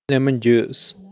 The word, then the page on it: lemon juice: /ˈlemən dʒuːs/